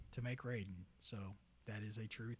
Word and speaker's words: Raiden: Yep, but that's how we pronounced it in the game. It was pronounced Raiden.